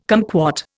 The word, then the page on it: cumquat: Indian